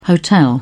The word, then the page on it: hotel: British English